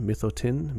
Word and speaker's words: Mithotyn: The band is called Mithotyn, at least that's how I've always pronounced it. M-I-T-H-O-T-Y-N, Mithotyn, Mithotyn.